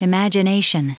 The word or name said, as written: imagination